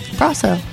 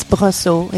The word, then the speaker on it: Brosseau: Brosseau. – Brosseau. – Yeah. – Didn't sound right with the "ow" at the end. – It's O. It's Brosseau. It's Brosseau. It's French.